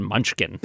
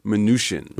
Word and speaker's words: Mnuchin: And I can tell you that officially the pronunciation is not Mnuchin, it's Mnuchin. – Like minutia. – Mm. As a wonderful email to Kai Rizdal once put it, the CH is soft, as in champagne or charlatan.